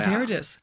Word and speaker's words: viridis: Yeah, Angiosanthus viridis. – Okay. Now, hang on. Let's test. Any idea what that was? – Angiosanthus, what was it, flavidus? – Viridis, V-I-R. – Viridis.